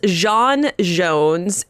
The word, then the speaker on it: J'onn J'onzz: So his name is John Jones, and I am pronouncing it like that because that is the way that Andrew Kreisberg and Jeff Johns do it, so I trust them. It is spelled J-O-N-N, J-O-N-Z-Z.